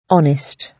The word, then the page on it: honest: British English